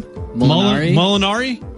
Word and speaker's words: Molinari: Francisco Marinara? What do we have here? How do you say his name? – Molinari? Francisco Molinari? – Yes.